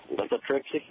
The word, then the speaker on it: lithotripsy: The procedure was lithotripsy.